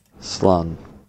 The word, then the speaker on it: Slán: Slán. – Slán. – Slán. Yeah. – So Slán. – It rhymes with Sean, but like it's pronounced differently because it's a different word. Slán.